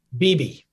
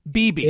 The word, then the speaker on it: Bebe: What is the correct pronunciation? – Bebe. – Bebe. – It's Cuban for baby.